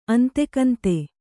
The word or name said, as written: ಅಂತೆಕಂತೆ